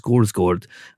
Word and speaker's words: Skarsgård: The gentleman who advised me a couple of weeks ago that Alexander Skarsgård is actually pronounced Skorsgård,